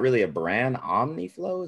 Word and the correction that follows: Umiflow: is that what it's called? – Umiflow, yeah. – Umiflow, yeah, okay. Sorry, I didn't mean to completely butcher that.